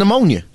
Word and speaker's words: pneumonia: Well, the P is silent in pneumonia.